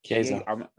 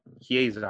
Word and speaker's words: Chiesa: was it Chiesi or Chiesa. – Chiesa I might be pronouncing it wrong.